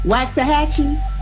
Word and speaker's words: Waxahachie: Waxahachie? – Waxahachie. Waxahachie.